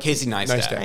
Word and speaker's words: Casey Neistat: Casey Neistat.